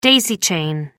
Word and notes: daisy chain: American English

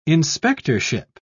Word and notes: inspectorship: British English

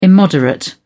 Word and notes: immoderate: British English

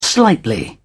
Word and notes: slightly: British English

